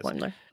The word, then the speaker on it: Boimler: It's Ensign Boimler, I think is Boimler. How you pronounced it. Yes. – Boimler.